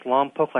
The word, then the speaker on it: Lompoc: It's pronounced Lompoc like Slowpoke.